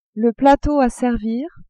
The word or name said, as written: plateau a servir